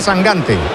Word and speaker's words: Sangante: Se pronuncia Sangante. – Yo pronuncio como me digáis. – Bueno, eso he leído yo, que dice que es Sangante. – De hecho, me ha dicho, tú acuérdate de Mangante.